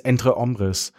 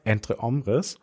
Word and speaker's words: Entre Hombres: Die nächste Serie, die da bei mir auf jeden Fall reinfällt, ist Entre Hombres, die ich eine Zeit lang immer hier als Entre Hombres ausgesprochen habe, aber ich bin ja lernfähig und nachdem ich jetzt zu Hause 15 Mal vom Spiegel gelernt habe, Entre Hombres, in der englischen Übersetzung Amongst Men.